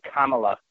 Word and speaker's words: Kamala: Kamala Harris. I'm going to pronounce it correctly, Kamala.